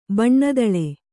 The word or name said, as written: ಬಣ್ಣದಳೆ